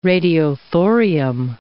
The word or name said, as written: radiothorium